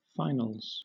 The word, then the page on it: finals: British English